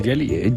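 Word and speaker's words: Geely: La marca, ¿me dirás bien cómo se pronuncia? ¿Gili? – Vamos a decir Jelly.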